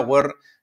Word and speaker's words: were: ese escrito were, que tendemos a decir siempre we're o were o lo que sea, were, la pronunciación es were, were, u y una r genérica, were, were, were, were, we were here.